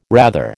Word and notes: rather: American English

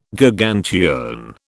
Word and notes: Gargantuan: British English

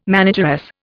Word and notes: manageress: Indian

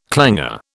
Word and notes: clanger: British English

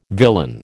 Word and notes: villain: American English

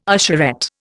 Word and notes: usherette: Indian

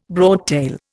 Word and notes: broadtail: Indian

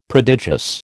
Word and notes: prodigious: American English

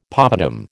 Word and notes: poppadam: American English

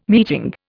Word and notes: meeting: Indian

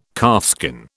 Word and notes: calfskin: British English

